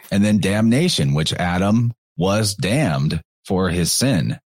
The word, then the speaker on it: Atem: was Adam Ra, spelled A-T-E-M, but pronounced it almost exactly as Adam, proving quite well that the T and the D can be interchanged as, you know, a lot of letters interchange.